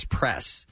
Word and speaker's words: Press: So, you know, my shortened name is Press. But it's two syllables away. She says it's Prayus.